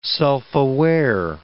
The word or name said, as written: self-aware